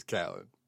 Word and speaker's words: Khaled: Apparently he tweeted and he said, Khalid, quote, is pronounced Khalid. So it is Khalid. – Khalid. Okay. Mm-hmm. All right. Yeah, I need to get that through my head. Khalid. And then DJ Khaled is Khaled.